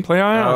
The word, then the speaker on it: Plaion: Play-On. – Oh, God. They can't win. – Nope. – Can't win. But they did release a trailer today that does end with them clearly pronouncing Smart. – Play-On.